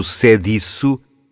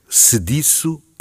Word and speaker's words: sediço: Ao ler um texto de José Saramago, do seu último livro As Intermitências da Morte, pronunciei erradamente a palavra sediço. Abri o E pronunciando sediço, obviamente um erro. Sediço é que está correto.